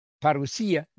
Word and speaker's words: parousia: So we're messianic in our outlook, believing in the end time, parousia. We use the Greek pronunciation, the modern Greek pronunciation in our Greek, which is a pretty language.